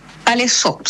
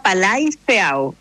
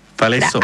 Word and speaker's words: Palaiseau: Cuando yo llegué, no sabía pronunciarlo porque tiene muchas vocales. – Ya. – Yo no sabía francés. Entonces yo decía Palaiseau y no es Palaiseau. – Palaiseau. – La A I es E, claro, y la E, E A U es O.